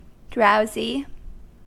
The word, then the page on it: drowsy: American English